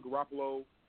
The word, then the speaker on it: Garoppolo: The strength of this particular team is not, and I'm not getting ready to put Garoppolo.